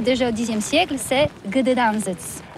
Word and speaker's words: Gdańsk: C'est pourquoi on dit Gdańsk.